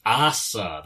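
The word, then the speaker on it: Асса: That's Asa, that's right. I was pronouncing it wrong in the intro, but never mind.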